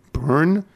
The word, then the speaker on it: Boerne: Within a day's drive, I can get to B-O-E-R-N-E, Bern, New Braunfels.